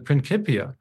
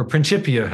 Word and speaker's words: Principia: the Principia, or Principia, depending on how you like your Latin pronounced, it had a subtle theological implication.